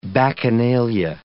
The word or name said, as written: bacchanalia